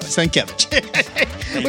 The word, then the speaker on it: Sienkiewicz: Now, hold on. I know you know how to say his name. – I know. Sienkiewicz.